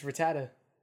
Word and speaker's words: Rattata: You pronounce it the same way Nikki does. – PP? – No, Rattata. It's Rattata. – Rattata.